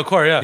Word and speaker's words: Okur: but Mehmet Okur. – Yeah, yeah, Mehmet Okur.